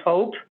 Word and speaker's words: HAWP: That's when you take the first four letters, it looks like it's spelled H-A-W-P, hope, but we call it hope.